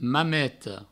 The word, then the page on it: Mameta: Mameto